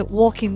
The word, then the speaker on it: Walking: Y no podía ser de otra manera de que tuviéramos un review de The Walking Dead, que hoy me han enseñado a pronunciarlo bien, que la L es muda, The Walking Dead, temporada 10, parte A, que nos hacen esto de las partes A y las partes B.